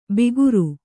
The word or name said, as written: ಬಿಗುರು